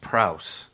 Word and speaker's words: praus: It's actually pronounced praus in Greek for anyone out there who's interested in the Greek.